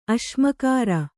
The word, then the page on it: ಅಸಮಗ್ರ: asamagra